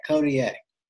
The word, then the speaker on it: Kodiak: Kodiak? – Yeah, Kodiak.